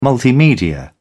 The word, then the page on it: multimedia: British English